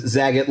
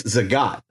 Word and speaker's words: Zagat: Yeah, so I thought it was Zagat like maggot, but it's Zagat.